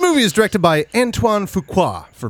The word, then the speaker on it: Fuqua: Fuqua is the director of Training Day,